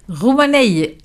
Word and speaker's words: Roumanaï: Donc la recette de l'émission d'aujourd'hui, c'est Roumanaï. Est-ce que la prononciation est bonne? – Roumanaï. – Ah voilà. Hein, on sent que tu as de l'expérience.